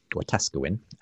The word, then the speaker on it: Wetaskiwin: Now, without going into the specifics of that debate, um, Mark in, I think it's pronounced Westaskewin, Canada, Wetaskiwin, um, uh, wants to ask about how we uh, live um, in unity despite profound theological and ethical differences as Christians.